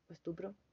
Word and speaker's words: estupro: se considera estrupo estupro, estupro. Siempre se me olvida cómo se dice esa palabra. Estoy muy boluda hoy. Estupro o estrupo. Estupro, ya.